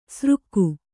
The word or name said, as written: ಸ್ರುಕ್ಕು